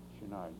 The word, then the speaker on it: Chouinard: So I think thereafter, Chouinard, yes. – How do you spell it? – C-H-O-U-I-N-A-R-D. C-H-O-U-I-N-A-R-D.